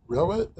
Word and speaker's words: Roet: We have Emily Roet. Is that how you pronounce it? – Yeah, Roet, like poet with an R.